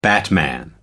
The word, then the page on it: batman: American English